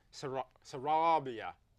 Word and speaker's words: Sarabia: My guest tonight is Armando Sarabia. Did I say that right? – It's Sarabia. – Sarabia? – Sarabia. – Sarabia. – Roll the R. Roll the R. – Sarabia.